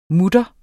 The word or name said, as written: mutter